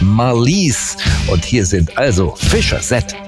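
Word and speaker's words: Fischer-Z: Ganz einfach, die Band war sehr lustig drauf und hat sich gedacht, wir nehmen einen Namen, der so ähnlich wie Fish's Head klingt. Ausgesprochen Fischer Set, ja, also Fischkopf.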